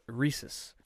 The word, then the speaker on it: rhesus: Rhesus. R-H-E-S-U-S. Rhesus. I think that's how you say that.